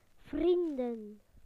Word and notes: friends: British English

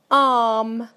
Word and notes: arm: British English

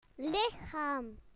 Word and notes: body: British English

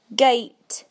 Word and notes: gate: British English

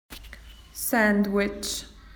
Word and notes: sandwich: Australian English

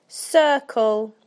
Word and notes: circle: British English

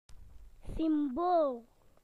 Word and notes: symbol: British English

